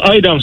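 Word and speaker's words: Eidam: Říkáš a vyslovuješ Aidam nebo Aidam? Uh, eh, No, a i dám samozřejmě, ale jestli s tím má někdo problém, tak ať si tam dá goudu, že jo?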